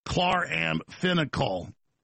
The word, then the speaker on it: chloramphenicol: Spelled K-L-A-R. A-M-F-E-N, or pronounced, spelled C-H-L-O-R-A-M-P-H-E-N-I-C-O-L, pronounced chloramphenicol, chloramphenicol.